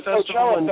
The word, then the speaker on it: Coachella: Oh, the Coachella Festival.